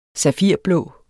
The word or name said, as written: safirblå